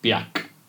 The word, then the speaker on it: Björk: Well, everyone who knows how it's properly pronounced calls it Björk, which is Icelandic for birch tree.